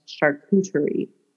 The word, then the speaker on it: charcuterie: But I would like you all to know that it's pronounced charcuterie.